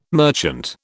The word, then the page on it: merchant: British English